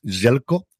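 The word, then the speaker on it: Željko: no es Zelko, es Jelko, así es como Jelko se pronuncia, y te da, ¿qué queréis que diga?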